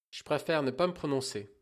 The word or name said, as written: prononcer